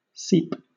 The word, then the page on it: seep: British English